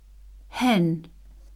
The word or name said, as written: hen